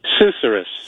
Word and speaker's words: susurrus: but you used the word Susurrus? Am I saying that right? – Susurrus. – Susurrus. Yes.